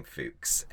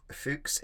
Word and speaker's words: Fuchs: Leonard Fuchs is a 16th century Bavarian botanist, and I'm sure you would not be pronouncing his name Fuchs, F-U-C-H-S.